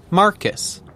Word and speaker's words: Marcus: Marcus. – Can you spell that, please? – M-A-R-C-U-S.